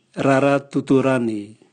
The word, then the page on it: rara tutulani: Raga